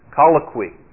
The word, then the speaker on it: Colloquy: Colloquy. Is that right? Colloquy. I can't even pronounce it now. Colloquy. That's it.